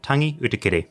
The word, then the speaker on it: Utikere: In today's episode of our MPs in Depth series, I sit down with Labour MP for Palmerston North, Tangi Utikere.